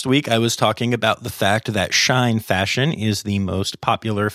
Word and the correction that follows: Shein: And um, apparently uh, it is pronounced Shein. Um, even though it is spelled kind of like shine, it is "she in fashion.